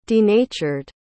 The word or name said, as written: denatured